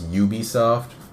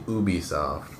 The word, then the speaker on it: Ubisoft: I always thought it was Ubisoft. It's actually Ubisoft.